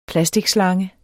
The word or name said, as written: plasticslange